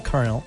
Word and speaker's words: colonel: Es lo mismo que, por ejemplo, coronel, que se pronuncia con R, que es coronel. – Colonel. – Se escribe con L, colonel.